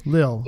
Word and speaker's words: Lille: In Lille? Did I say it correctly? Lille. – Yeah.